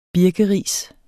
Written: birkeris

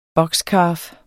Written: boxcalf

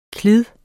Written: klid

